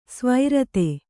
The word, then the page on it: ಸ್ವರಿತ: svarita